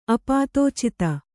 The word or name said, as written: ಅಪಾತೋಚಿತ